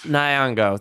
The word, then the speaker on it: Nyong'o: Lupita Nyong'o, I believe. Nyong'o, thank you.